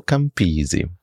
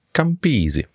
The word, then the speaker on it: Campisi: Ich heiße Danilo Campisi. Auf Italienisch ist es so, man betont meistens die vorletzte Silbe, deswegen mhm. – Danilo Campisi.